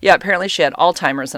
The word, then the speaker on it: Alzheimer's: They say Alzheimer's and old timers. What? Where are you coming up with this? Like, how are people coming up with these words? It's Alzheimer's. There's a Z in there.